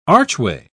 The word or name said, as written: archway